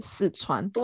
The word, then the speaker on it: 四川: 嗯，他们不知道真真正的发音是四川，他们就是四川。